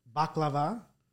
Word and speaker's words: baklava: I got baklava.